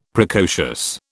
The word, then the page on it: Precocious: British English